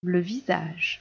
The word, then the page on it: le visage: French